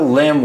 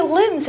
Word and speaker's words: limb: So I could use an example sentence like saying, my friend Mary injured her limb while hiking in the forest and had to get medical help. – 所以 limb 这个字呢指的是肢体，那它的发音要注意哦， L-I-M-B 结尾的字啊有一个字母不发音，那个字母就是 B。